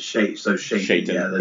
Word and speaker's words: Shaitan: And Baron Karza comes blasting in, Prince Aragorn is killed, and there is Baron Karza standing there with, I pronounced it Shaitan. – It's supposed to be like Satan. – Okay. – Well, it's Shaitan.